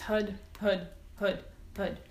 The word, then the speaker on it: Har: The chant is HUD. It's pronounced HUD. It's written H-A-R, HAR, but it's HUD.